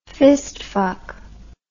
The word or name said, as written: fistfuck